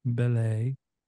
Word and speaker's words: Belay: Um, Belay, but it it's spelt like belly, a little bit, kind of.